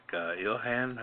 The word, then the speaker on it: Ilhan: Looks like Ilhan. How do you pronounce it? Yeah, that's right.